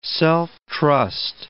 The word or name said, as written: self-trust